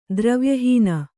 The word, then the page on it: ದ್ರವ್ಯಹೀನ: dravya hīna